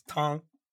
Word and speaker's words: Tang: It's not Tang. It's Tong.